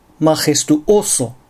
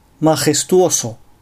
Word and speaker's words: Majestuoso: アクセントに気を取られるあまり、アディオス、エスタシオン、シトアード、マジェストオソのように、単語の途中に正門閉鎖を入れてしまう。こういう発音をするとスペイン語としては大変聞き苦しい感じになります。ここは正しく Adios Estación Situado Majestuoso と発音したいところです。